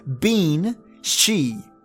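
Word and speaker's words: bean sí: In Irish Gaelic, it can be spelt banshee, so instead of S-H-E-E, it's S-H-I-E. Or even bean, she, and ben, side. But remember, the Irish language pronounces it different. So we spell it B-E-N-S-I-D-E. They would say it as bean she.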